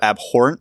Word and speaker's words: abhorrent: abhorrent and heinous. – Abhorrent. The H is silent.